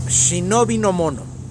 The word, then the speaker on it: shinobi no mono: El kanji, palabra escrita en japonés, apropiado, se pronuncia como shinobi no mono o shinobi para cortar.